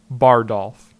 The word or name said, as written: Bardolphn